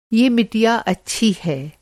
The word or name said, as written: मिटिया